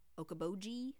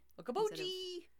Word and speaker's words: Okoboji: In reality, it is O-K-O-B-O-J-I. However, for some reason, in multiple times during the Conduit episode, they spell it O-K-O-B-O-G-G. – Oh. – Like the Bee Gees. Okoboji. – Okoboji.